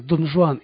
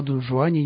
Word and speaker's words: Дон Жуан: Во многом благодаря успеху его пьесы «Дон Жуан» или «Каменный пир» мы произносим испанское имя Хуан на французский манер – «Дон Жуан».